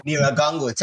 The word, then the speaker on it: Nyiragongo: With your time in the Republic of Congo, I believe it's in the Mount So we climbed to Mount Nyiragongo. – Nyiragongo. Thank you for that pronunciation.